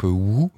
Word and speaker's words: W2O: Trois morceaux très différents dans le laboratoire à l'instant, tout à l'instant, juste ce qui vient de passer, c'est le groupe Wu, qui a sorti en 2015, alors ça s'écrit W2O, qui a sorti en 2015 un magnifique disque intemporel et en même temps sans référence, très étrange.